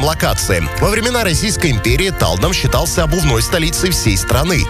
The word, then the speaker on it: Талдом: Подмосковный город удивляет туристов уже одним названием с непривычным поначалу ударением на первый слог. Отправляемся в Талдом.